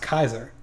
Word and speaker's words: Caesar: Keep in mind that in Latin, it's pronounced Kaiser.